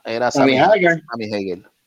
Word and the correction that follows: Hagar: o Hagar, como se pronuncia.